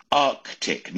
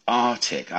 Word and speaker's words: Arctic: Arctic, not Arctic.